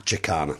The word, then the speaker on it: Jacana: Chisana, J-A-C-A-N-A, railway station. – Chicana. – There we go.